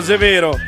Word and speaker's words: severo: Severo, severo. – Ma non si dice con la E aperta, severo. – Severo, severo, sì, esatto.